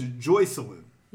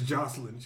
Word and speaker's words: Joycelyn: I was watching the morning show that Gail is on, and she was like, her name is Joycelyn. – Yeah. – And he says Jocelyn.